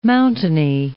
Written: mountainy

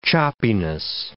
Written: choppiness

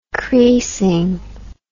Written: creasing

